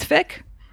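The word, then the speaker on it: Ficke: You know what rhymes with Fick? – What? – Tick.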